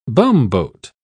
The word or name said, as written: bumboat